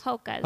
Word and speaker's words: Hokas: Is that how you say it? Hukas? – Hokas. – Hokas. Okay.